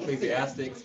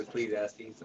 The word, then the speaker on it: Ecclesiastes: I won't call it Ecclesiastics, but it's Ecclesiastes.